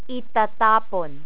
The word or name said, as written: itatapon